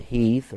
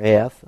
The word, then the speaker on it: Heth: Some call it Heath or Heth. I pronounce it with the H silent.